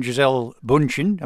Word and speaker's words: Gisele Bündchen: He and Gisele Bündchen, am I pronouncing that correctly? – Very well.